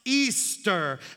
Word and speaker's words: Ishtar: Semiramis, she became known as Ishtar, which is pronounced Easter, and her moon egg became known as Ishtar's egg.